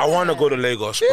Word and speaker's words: Lagos: Lagos.